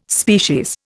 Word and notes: species: Indian

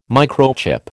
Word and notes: microchip: American English

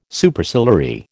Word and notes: Superciliary: American English

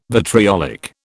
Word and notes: vitriolic: British English

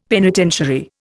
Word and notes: penitentiary: Indian

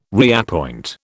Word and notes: reappoint: British English

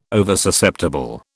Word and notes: Oversusceptible: British English

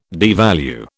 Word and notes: devalue: British English

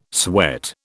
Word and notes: sweat: British English

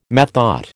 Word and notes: methought: American English

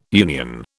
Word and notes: union: British English

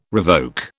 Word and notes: revoke: British English

